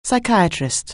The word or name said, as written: psychiatrist